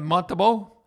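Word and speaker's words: Montembeault: And how do you pronounce that guy's name? Sam Montabeau? – Yep.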